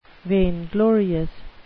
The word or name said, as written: vainglorious